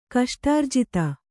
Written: ಕಷ್ಟಾರ್ಜಿತ

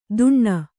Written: ದುಣ್ಣ

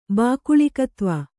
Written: ಬಾಕುಳಿಕತ್ವ

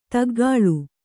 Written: ತಗ್ಗಾಳು

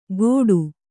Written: ಗೋಡು

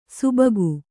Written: ಸುಬಗು

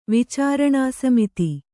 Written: ವಿಚಾರಣಾಸಮಿತಿ

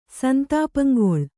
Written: ಸಂತಾಪಂಗೊಳ್

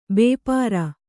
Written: ಭಪ್ಪರೆ

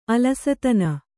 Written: ಅಲಸತನ

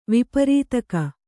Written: ವಿಪರೀತಕ

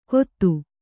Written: ಗೊತ್ತು

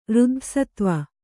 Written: ಋದ್ಧಸತ್ವ

